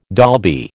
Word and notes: Dolby: American English

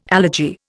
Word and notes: allergy: Indian

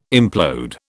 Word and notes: implode: British English

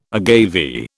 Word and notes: agave: British English

